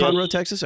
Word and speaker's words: Conroe: So, Todd, you are co-founder of the Lone Star Throwdown in Conroe, Texas. Am I saying it right? – Yes. – Conroe, Texas. Okay. – Yes, that's correct.